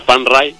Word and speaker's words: Phan Rai: Entonces, en tailandés se pronuncia Phan Rai.